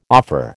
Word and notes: offer: American English

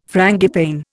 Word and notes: frangipane: Indian

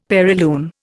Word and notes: perilune: Indian

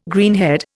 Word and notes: greenhead: Indian

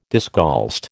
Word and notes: discalced: American English

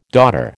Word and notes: daughter: American English

